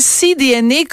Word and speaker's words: CDNA: J'espère que je prononce bien le nom de votre entreprise, CDNA comme l'ADN de la mer. Oui, exactement. Est-ce que dans votre entreprise, vous ne faites que de la viande de phoque ou il y a différents produits marins?